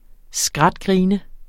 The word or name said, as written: skratgrine